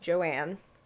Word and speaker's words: Joan: So, Joanne, now her name is spelled Joan, J-O-A-N, but it's pronounced Joanne.